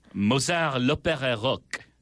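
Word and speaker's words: Mozart l'Opéra Rock: Am I saying that right? L'Opera or L'Opera? – Mozart L'Opera Rock.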